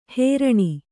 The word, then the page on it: ಹೇರಣಿ: hēraṇi